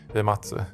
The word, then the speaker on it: Uematsu: Uematsu, mm.